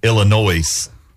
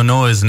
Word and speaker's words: Illinois: Today, from Illinois. – There is no noise in Illinois. – It's noise. – The S is silent.